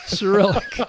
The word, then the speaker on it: Cyrillic: It's not in uh, what is it, acrylic or something, or not acrylic. It's acrylic. Cyrillic. you were close.